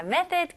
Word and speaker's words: method: 발음은요, 여기서 th 발음을 잘 해주셔야 되겠죠? method, okay? th 발음이 아직도 어렵게 느껴지시면 살짝 메롱으로 혀가 조금 나와야 돼요.